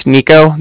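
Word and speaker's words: Niko: All right, like I said, my name's Nico. That's N-I-K-O.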